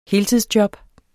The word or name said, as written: heltidsjob